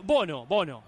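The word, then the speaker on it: Bounou: ¿Qué le digo, Bonu o Bono? – Bono. Ah, Bono. Yacine Bono. – Hoy me mandó el Budo una pronunciación y sí. – Bono. – Sí, todo rápido. Sí, Bono. Sí, si querés es un poco más francés, pero más conocido por nosotros como Bono.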